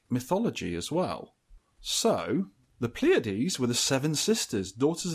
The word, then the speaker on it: Pleiads: This is Pleiades, pronounced Pleiades.